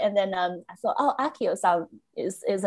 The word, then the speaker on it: Accio: It's Accio. three syllables.